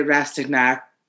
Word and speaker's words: Rastignac: R-A-S-T-I-G-N-A-C. Chateau de... – Mm-hmm. – Rastignac? – No, okay. You would see... You say the C. – Oh, okay. – You pronounce the C. – Do you say knack like a big ol' American? – Yes.